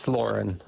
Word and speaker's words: Loren: Was it Lauren or Loren? – It's Lauren. – Lauren. Okay. – L-O-R-E-N.